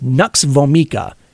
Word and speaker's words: Nux vomica: So there's Nux, Nux vomi, I don't even know how to say this, Nux vomica, N U X V O M I C A.